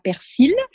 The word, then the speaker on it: persil: Dans la plupart des départements, on dira persil. Il n'y a que dans quelques départements, plutôt vers le centre de la France, où on ne prononce pas le L.